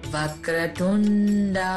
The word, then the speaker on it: Vakratundaya: Maintenant il y a un autre mantra qui Om Vakratundaya Hum. Quand vous faites ça, c'est Om Vakratundai Hum. C'est ça qui se prend proper way of pronunciation.